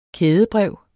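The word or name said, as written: kædebrev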